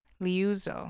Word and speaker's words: Liuzzo: And two of the main players in that were Viola Liozzo I don't want to mispronounce her name. Liuzzo, I'm sorry.